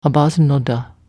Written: abazenoda

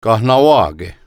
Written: Kahnawake

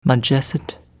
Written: Majesset

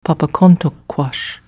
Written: Papacontuckquash

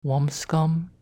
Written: Womscom

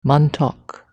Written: Montauk

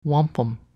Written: wampum

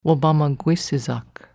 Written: wobamagwsizak

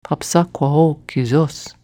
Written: Papsapqhao kesos

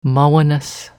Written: Mahweness

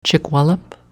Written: Chickwallop

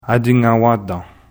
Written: Attignawantan

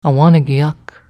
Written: Awanigiak

